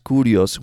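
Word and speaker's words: Curios (Κύριος): It seems that even though the name was written in Paleo Hebrew, it was intended to be pronounced as Curios when read aloud.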